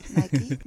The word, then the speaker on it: Nike: Zeg ik dat goed, Nike? – Ja, Nike, ja.